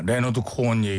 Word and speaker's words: 레너드 코헨: 그게 뭐냐면 바로 로큰롤 홀 오브 페임 시상식에서 거기에서 레오너드 코헨에게 상을 준 게 줄 때 같이 나왔어요. – 야 레너드 코헨 얘기하시는 거죠? – 네 레너드 코헨 발음 이상하게 써가지고 다른 사람인 줄 알았어요.